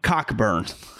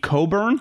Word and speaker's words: Coburn: that is not how you say his name. It's Kofi Coburn. Coburn. I've been saying it wrong for two weeks. Uh, I learned that this week that it's Coburn.